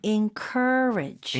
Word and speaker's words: encourage: 스펠링은 EN으로 시작하지만 발음은 IN으로 하시면 좋아요. – 아. – 왜냐면 강세가 cur에 들어가요. – Okay. – 그래서 앞부분을 약하게 하는 거예요. Encourage.